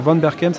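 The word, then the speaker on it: Van Berchem: Alors Van Berken, ça s'écrit V-A-N, plus loin B-E-R-C-H-E-M.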